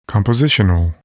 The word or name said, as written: compositional